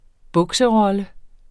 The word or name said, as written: bukserolle